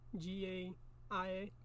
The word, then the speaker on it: Gaia: So then it goes on to say that chaos gives birth to, um, God damn it, how do you say it? Gaia? G A I A? Yeah, Gaia. Which, you know what that chaos is, right? – Mm in the beginning.